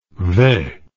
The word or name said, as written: ve